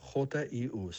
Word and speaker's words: HU: La palabra Hugh se escribe HU, se pronuncia JIU, suena así.